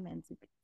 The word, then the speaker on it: Mantzoukas: Jason Mantzoukas, yeah.